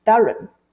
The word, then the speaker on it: Durham: There's a fun story because the Duke is in North Carolina and the city is called Durham, B-U-R-A-M. And then before I came here, I searched for the pronunciation, and then the English pronunciation is Durham.